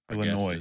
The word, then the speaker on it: Illinois: But it made me think about our guest today, you going to Illinois and me watching those because Illinois. It's not noise. – Illinois. – It's not Illinois. It's a silent S.